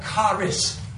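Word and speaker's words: Charis (χάρις): Okay, phonetically it'd be spelled C-H-A-R or C-H-A-R-A. Can you see that up there? Joy. The word for grace is what? Charis, right? C-H-A-R-I-S, right? The word for gift, charisma. You've heard of it. Person's got charisma, right?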